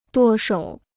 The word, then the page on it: 舵手: duò shǒu